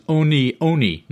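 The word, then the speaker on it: One One: And I have been chastised by some semi-alert listeners that the proper pronunciation is Oni Oni, not 1-1.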